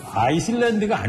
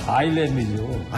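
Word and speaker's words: Islands: 아이슬란드가 아니죠. – 뭡니까? – 아일랜드죠. – 아일랜드니까? 왜 근데 S는 묵음이죠, 묵음.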